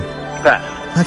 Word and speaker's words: Pat: Pat. – Okay, Pat, rhymes with cat.